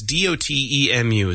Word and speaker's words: Dotemu: Dotemu, Dotemu, Dotemu, things that people don't know how to pronounce. I can't stand it. But it's Dotemu is the company that made this game.